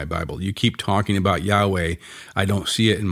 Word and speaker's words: Yahweh: When I say probably pronounced Yahweh, it's because ancient Hebrew was written without vowels, just consonants. And so all we have in the ancient Hebrew text are the consonants of God's name. And to transliterate those into English letters, it would be YHWH. Now, for reasons I'm not going to go into, most Old Testament scholars say that would be pronounced Yahweh.